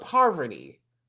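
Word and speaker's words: Parvati: Not Poverty, but Parvity. – Parvity. Oh, okay. You have to enunciate.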